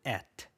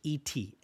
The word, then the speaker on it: et: There's actually several words in Latin for and, but the most common one is the word et, which is et, et. Okay. In French, we would not pronounce that T very heavily, but in Latin, we pronounce that consonant, so it's et.